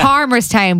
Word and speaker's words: Palmerstown: Palmerstown, but the L is silent.